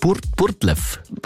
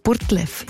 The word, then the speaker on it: Burgdorf: Wie spricht man Burgdorf aus dort im Dialekt Burtlef? – Ich glaube Burtlef.